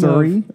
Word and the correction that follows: Shuri: Shuri? How do you say her name? – Shuri.